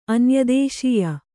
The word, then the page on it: ಅನ್ಯದೇಶ್ಯ: anyadēśya